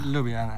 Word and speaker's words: Ljubljana: Ljubljana, yeah.